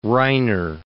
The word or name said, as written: Reiner